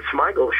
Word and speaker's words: Smigel: Robert Smigel, My God, Robert Smigel, does he pronounce it Smigel with a sh or sma? I always thought it was Smigel. He's brilliant. – Smigel, yeah.